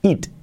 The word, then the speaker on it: eat: Pero el verbo, sobre todo, eat, aunque se pronuncia eat, la pronunciación es it it it it it Así, it. Número 4. Dormir. Escrito S-L-E-E-P.